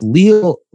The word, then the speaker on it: Leal: And I love Jordan Davis, but Leal, pronounce it like he's a Brazilian. If Leal isn't your consensus top interior defensive line, I don't know what we're doing at this point.